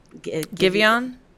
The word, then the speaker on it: Giveon: How do you pronounce that R&B singer's name? – Giveon.